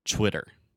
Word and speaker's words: Twitter: So the first sound, you are actually still making that ch, the ch, when I say chwi, but I'm adding the w sound at the end. So I'm making a circle with my mouth and saying Twitter.